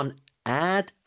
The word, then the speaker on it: an ad: A nad. A nad. 왠지 N을 뒤에 붙여서 발음하는 것처럼. I saw a nad.